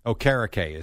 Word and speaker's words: Okereke: Okereke is how all Okereke. – The... Okereke. – Okereke. – Yes, that's how all the announcers call them.